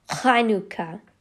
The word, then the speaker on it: Hanukkah: And it's, yeah, it is pronounced with a guttural KH sound, like Hanukkah, okay.